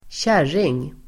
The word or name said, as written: kärring